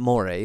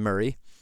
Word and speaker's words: Moray: Murray is spelled Moray, for those Americans who are not familiar with this, like I had to learn that once upon a time. It's not Moray, it's Murray.